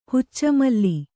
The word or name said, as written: ಹುಚ್ಚಮಲ್ಲಿ